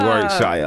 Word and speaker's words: Warwickshire: Did you like the way I said Warwickshire? – I do.